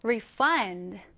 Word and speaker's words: refund: refund가 동사로 쓰일 때, 즉 환불하다라는 의미로 쓰일 때는 강세가 뒤에 들어갑니다. refund, refund 이렇게요. 그런데 명사로 쓰일 때는요. 앞에 강세가 들어가게 됩니다. 그러면 refund, refund가 되겠죠? 자, 여러분, 환불하다라는 의미일 때는 refund, 환불일 때는 refund 이렇게 발음하시면 됩니다.